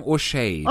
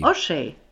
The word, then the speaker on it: O'Shea: Äh, ich würde sagen O'Shea. – O'Shea, okay, okay. – Wie, wie, wie Hey, O'Shea.